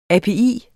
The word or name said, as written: API